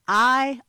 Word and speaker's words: Ai: But, Grant, there's one place that beats all of them, and it's called I, Ohio, and that's spelled A-I. It has about 600 people there, and it's I, Ohio.